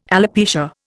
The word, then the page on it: alopecia: Indian